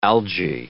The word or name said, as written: algae